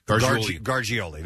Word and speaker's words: Gargiulo: So prosecutors have described this guy, Gargiulo. I'll never get this name right. – I think it's a soft G. – Gargiulo. That's better.